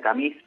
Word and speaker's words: Camus: Este, si me permitiste, quisiera regalar eh, a vos y a la audiencia un texto para finalizar de Albert Camus, se escribe Camus, pero se pronuncia camis porque es francés, que se llama El Verano.